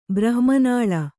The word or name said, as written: ಬ್ರಹ್ಮನಾಳ